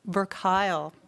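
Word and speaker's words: Verkuil: Oh, and she told me last night how I pronounce this, Verkile.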